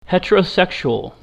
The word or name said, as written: heterosexual